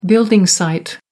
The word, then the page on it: building site: American English